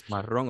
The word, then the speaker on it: marrón: No, that's how you say it. It's marrón or brown.